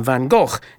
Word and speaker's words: van Gogh: Incidentally, I did check the pronunciation of the artist's name, and van Gogh is generally accepted to be best English approximation of the Dutch original.